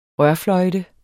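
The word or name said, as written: rørfløjte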